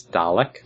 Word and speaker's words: Dalek: Much like Dalek, we don't add R's to things. It's Dalek.